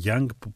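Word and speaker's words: Younge: τον συνθέτη και παραγωγό Adrian Young, που προφέρεται Young παρόλο που έχει το «ι» στο τέλος.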